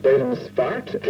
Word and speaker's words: Dedemsvaart: It's Post Office Box 41-7700AA, Dedemsvaart, which is pronounced Dedemsvaart in Holland. And the V is actually pronounced as an F.